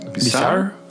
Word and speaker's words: bizarre: ¿Cómo se pronuncia en, ¿cómo se pronuncia en inglés? – Bizarre.